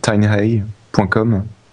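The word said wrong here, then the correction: TinEye: C'est T-I-N-E. E Tiny Eye.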